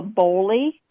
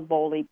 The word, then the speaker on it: Boley: If you just type in Angela Boley, portrait artist, my name will come up in Facebook.